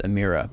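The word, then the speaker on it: Emira: And yeah, the Lotus, I said Amira in the video, and it's pronounced Amira. But the Avaya is Avaya. So yeah. It's like, that's a hard I. So I thought the Amira was also a hard I. It is a soft I.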